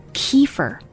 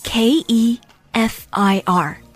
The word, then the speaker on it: kefir: 对，它叫 kefir。 – 哦耶，我就是想问的，这哎呀，真是我刚才想说半天说不是，就是这个 kefir。 – kefir K E F I R. This kind of probiotic drink is difficult to get the correct pronunciation. And certainly here in the US, the most often heard pronunciation is kefir. But a lot of people say that it's not correct. And in fact, the correct pronunciation should be kefir.